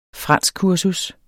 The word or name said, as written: franskkursus